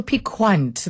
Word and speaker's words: piquant: Oh, so piquant, that's how it's said.